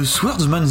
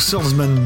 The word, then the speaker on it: Swordsman: Aujourd'hui, on va parler de Swordsman 2. Peut-être que je le prononce mal, il fallait pas prononcer le W, j'en suis vraiment désolé. Donc Swordsman 2.